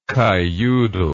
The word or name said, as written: kiyoodle